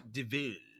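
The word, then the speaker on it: de Vil: It's devil, but it's pronounced DeVille.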